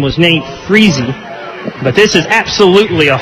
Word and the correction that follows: Friese: Randy, I believe I mispronounced his name. What were you going to say it was? – Freeze. – I was adding the extra E on the end.